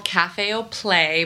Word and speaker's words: Cafe au Play: So it's called Cafe au Play, but it's spelled like Cafe au Lait with a P, so it's kind of a play on words.